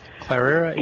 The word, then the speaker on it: Cleyera: Is it Clara? C-L-E-Y-E-R-A. – Oh, okay. Clara? Yeah, you got it right.